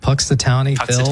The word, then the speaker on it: Punxsutawney Phil: Punxsutawney Phil. – Puxatawny Phil.